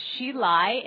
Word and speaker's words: Hsi Lai: It's Shelai, and it's spelled H-S-I-L-A-I.